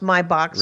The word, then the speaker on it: Maybach: And, yeah, you're right, Maybach, and it's spelled Maybach, but it's pronounced Maybach. So right. If you want to sound like an automotive expert, say it right.